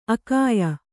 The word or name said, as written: ಆಖ್ಯೆ